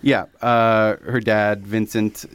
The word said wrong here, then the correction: Vincente: Is it Vincent or Vincente? – Vincente. Vincente, yeah. So we have a room full of Italians here, and I'm pronouncing it wrong.